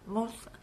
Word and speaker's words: Meunster: Is that how I'm saying it right? Is it Munzer? Yes.